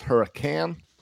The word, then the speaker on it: Hurricane: Also, I believe she said it's pronounced Hurricane.